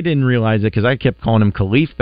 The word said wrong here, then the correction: Khalif: Battle, but it is indeed Caliph. That's how you say his name, Caliph Battle.